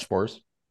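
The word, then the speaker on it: Sborz: How do you say his last name? The Evaldi's the guy who relieved Evaldi last night? – Spores, right? – Josh Spores. – Spores.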